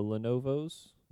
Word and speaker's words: Lenovo: And then the Lenovo's. Is that how you say it? – Lenovo? Yeah.